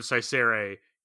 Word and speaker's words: Saicere: Yeah, and there it's also a hint as to how they're both pronounced, which I also like yeah, the fact that when she says Saicere, he hears Caesura means that the word Saicere, like we know that that C must be a soft C because he mistakes one word for the other.